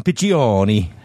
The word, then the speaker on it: Piccioni: Our old friend, Jason Piccioni. Am I saying Piccioni right? Piccioni. – Three syllables. – Piccioni. – Piccioni. It's like Piccioni. – It's like Pacino. – Okay. – Like Al Pacino.